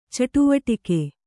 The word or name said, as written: ಚಟುವಟಿಕೆ